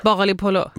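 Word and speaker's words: Bagalipolo (باقالی پلو): Men alltså bagapol eller vad det heter. – Bagalipolo. – Bagalipolo. – Det är alltså bagalipolo.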